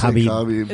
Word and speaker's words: Khabib: It's Khabib.